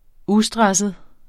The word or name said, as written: ustresset